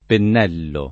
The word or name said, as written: pennello